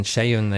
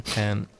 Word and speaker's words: Seun: S-E-U-N, Shayun, they say. – Shayun.